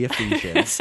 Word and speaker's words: Ephesians: This is a letter from Paul to the Ephesians.